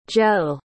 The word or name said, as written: gell